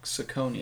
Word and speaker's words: Saucony: Is it Saucony or Saucony? – Yeah, I was going to say, I always pronounced it Saucony. – It's Saucony. – Saucony. – Well, you don't have to enunciate it like that, but it's Saucony.